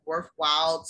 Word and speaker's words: Worthwhile: So it's W-Y-N-T-A and then Worthwhile. So W-O-R-T-H-W-H-I-L-E, all one word on Instagram.